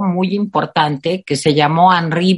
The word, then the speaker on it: Henri: Se escribe Henry, pero como es francés, se dice Henri Berson y tiene un libro sobre la risa.